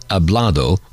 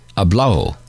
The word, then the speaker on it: hablado: Sometimes the Spanish do not pronounce the ado, so hablado would be said as hablao.